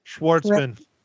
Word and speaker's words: Schwartzman: And Jason, and I can't pronounce his name. – Schwartzman. – Schwartzman. I kept wanting to say Schwarzkopf or something.